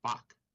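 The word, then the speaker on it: Bak (박): We call it Park, but it's Bak if you pronounce it in Korean. But to the English who heard that first, it sounded like Park.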